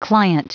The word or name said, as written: client